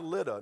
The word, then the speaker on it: Lydda: But I looked, it's pronounced Lydda.